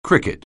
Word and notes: cricket: anglais

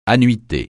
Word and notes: annuité: francais

